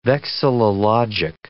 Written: vexillologic

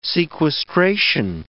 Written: sequestration

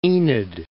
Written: Enid

